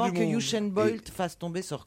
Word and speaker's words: Usain Bolt: Usain Bolt. – Usain Bolt. Moi, je prononce avec l'accent anglais.